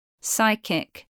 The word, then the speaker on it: psychic: PS spelling from Greek is silent P. Psychic.